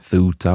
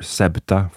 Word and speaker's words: Ceuta: I've seen a few different ways of pronouncing this name. I've seen Ceuta or Septa, for example, but for the sake of consistency, I'm going with this version.